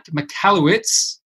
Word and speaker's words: Michalowicz: I am absolutely delighted to have with me this evening Mike Michalowicz. Did I pronounce it right? – You nailed it.